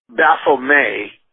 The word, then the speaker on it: Baphomet: Because Satan himself, when you see Baphomet, that's how you say it, not Baphomet.